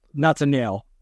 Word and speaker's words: Natanael: It looks like Nathaniel, but it's not, I promise. – Yep. Natanael.